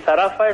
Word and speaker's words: Zarafa: Sí, Zarafa es una película de animación,